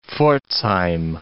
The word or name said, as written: Pforzheim